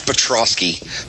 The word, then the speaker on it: Piotrowski: Oh, James, it's Petroski. P-I-O-T-R-O-W-S-K-I.